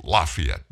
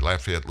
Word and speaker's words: Lafayette: Of course, those of you up north, you pronounce it Lafayette. But anyway, Lafayette, Louisiana.